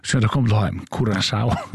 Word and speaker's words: Curaçao: Och vi kommer att säga att det landanavnet Curaçao uttalas Cura, K-U-R-A-S-A-U av vårskan Curaçao.